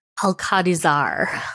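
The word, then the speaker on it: Alcadizzar: You pronounce his name very beautifully, and I don't. Alcadazar? – Alcadazar.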